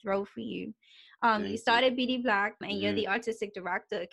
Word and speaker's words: BDBLAQ: We stand for BD Black. It's spelled BDBLAQ, and it stands for Bermudian blood, love and artistic quality.